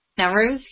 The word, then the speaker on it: Nowruz: Nowruz, is that how you say it? Nowruz, yes.